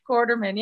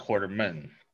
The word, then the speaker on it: Quarterman: Did I pronounce the last name right? – Quarterman. Yeah, perfect. – It's not like Alan Quartermain. It's Quarterman.